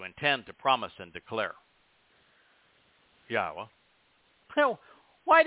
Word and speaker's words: Yahweh: Yahweh, the proper pronunciation of the name of Eloah, God, as directed in his Torah teaching regarding his Haya existence and our Shalom restoration. If you can say Eloah, Torah, Shalom, and Haya, then you can pronounce God's name.